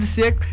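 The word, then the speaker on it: Džeko: Eu falo de Dzeko. Não, mas eu sei que é Dzeko corretamente. Eu falo que aqui no Brasil... – Fala de Dzeko.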